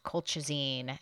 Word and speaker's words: colchicine: I noticed when I was editing this week's episode that I kept saying colchicine. I said it so many times, and I even knew how it's pronounced. It's pronounced colchicine.